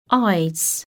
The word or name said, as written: eyes